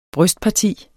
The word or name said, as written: brystparti